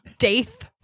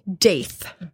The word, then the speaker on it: Daith: It's called the Daith. Piercing. I just needed to know. – Spell it. – D-A-I-T-H. – Really? – Daith.